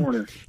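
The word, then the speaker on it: Hoerner: did you pronounce it Horner, Nico Horner? – Yes, Nico Horner.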